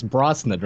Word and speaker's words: Brosnan: it's Brosnan, right? – Yes.